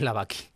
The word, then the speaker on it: Labaki: ¿Lavaki o Labaki, Labaki creo que es, ¿no? Sí. Como tú lo quieras pronunciar, está bien pronunciado. Nadine Labaki. – Eres el jefe, lo que tú quieras. – Pues eso, la línea editorial dice que Nadine Labaki Y el actor es Zain Al-Rafia.